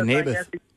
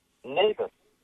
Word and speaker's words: Naboth: Yeah, Naboth. – Naboth.